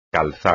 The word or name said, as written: calzar